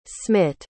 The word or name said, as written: smitt